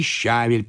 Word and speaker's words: щавель: Еще летом дачники щавель приносят. Так мы у них покупаем и в супермаркет относим». – «Извините, но вы опять неправильно говорите. В моем словаре указано, что ударение надо ставить на второй слог. Щавель».